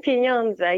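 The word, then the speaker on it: pieniądze: Forgive me if I don't say it correctly. Penunza. – Mm-hmm. – Penunza. – Pinyonza, yeah, it means money.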